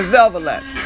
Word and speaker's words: Velvelettes: This is the Velvets, because let me say, we had the Marvelettes and the Velvelettes. That's who they were. That's how you pronounce it.